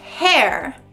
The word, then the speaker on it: hare: A primeira significa cabelo. H-A-I-R, all together, hair. E a outra palavra significa lebre. H-A-R-E, hair. Muito bom, a pronúncia é a mesma para as duas palavras, hair e hair.